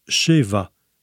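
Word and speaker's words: sheva (שבע): En hébreu, le chiffre 7 se prononce « sheva » et ça veut dire « rassasié ».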